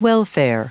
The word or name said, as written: welfare